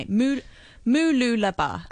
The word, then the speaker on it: Mooloolaba: Mooloolaba.